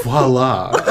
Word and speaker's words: voila: I pronounce viola. You're like, what did you just say? like, vi- viola. Isn't that how you say it? it's voila.